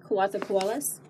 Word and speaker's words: Coatzacoalcos: Coatzacoalcos? – Yes.